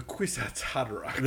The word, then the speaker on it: Kwisatz Haderach: The Kwisatz Haderach.